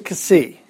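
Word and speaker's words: kasi (ξ): The next one is the letter, well, I would pronounce it kasi. And that this is one of the harder ones to pronounce. If you think of how do you pronounce the letter X? X. We'll take the e off and just go ks. That's the sound. Ks.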